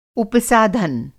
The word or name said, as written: उपसाधन